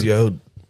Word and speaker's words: Yod: Is it Yod? It's Yod.